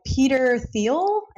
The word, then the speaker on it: Peter Thiel: The one that I kind of reference, Zero to One, by Peter Thiel, I think is yeah.